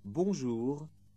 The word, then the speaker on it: bonjour: In this section, pay particular attention to the R sound in au revoir and at the end of bonjour and bonsoir.